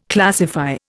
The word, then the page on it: classify: Indian